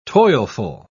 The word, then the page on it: toilful: British English